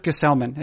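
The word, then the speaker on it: Gsellman: Gesellman. This is how I've learned it as I finish up the Mets list, which is done, by the way. – Okay. It's going to be Robert Gesellman and not Ty Black.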